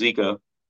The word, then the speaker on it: Jika: It, so it, it's pronounced Zika rig. It, it's spelled J I K A, but it's pronounced Zika.